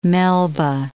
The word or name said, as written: Melba